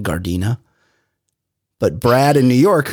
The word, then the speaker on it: Gardena: I often purposefully mispronounce things, and the song, while great, is especially great because of the mispronunciation of the city, the great South Bay city of Gardena as Gardenia.